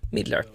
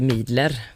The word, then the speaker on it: Midler: Bets Midler, som jag får hjälp att uttala hennes efternamn, M-I-D-D-L-Ö-R, Midler. Midler, så att det inte blir Midler.